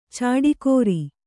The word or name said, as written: ಚಾಡಿಕೋರ